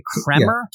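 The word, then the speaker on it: Kremer: Kramer.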